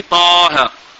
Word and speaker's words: طه: Les deux lettres Taha. On lit Taha. Mais dans l'écriture on met Ta et Ha.